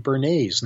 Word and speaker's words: Bernays: whose name was Edward Bernays, not spelled like the sauce, Bernays.